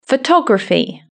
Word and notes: photography: British English

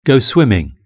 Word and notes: go swimming: British English

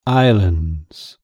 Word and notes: islands: British English

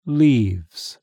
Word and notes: leaves: British English

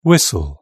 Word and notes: whistle: British English

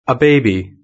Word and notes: a baby: British English

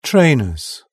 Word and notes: trainers: British English

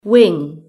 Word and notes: wing: British English

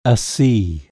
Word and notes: sea: British English

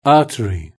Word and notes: artery: British English

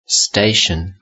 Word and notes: station: British English

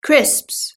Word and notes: crisps: British English

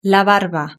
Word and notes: la barba: Spain Spanish

